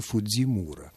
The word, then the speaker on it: Фуджимура: то вы должны знать, что вот эту фамилию только англичане произносят так, а на самом деле это Фуджимура. Вот так.